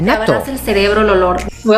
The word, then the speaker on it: natto: ¿Han oído ustedes hablar de nato? Así con doble T se escribe, N-A-T-T-O, nato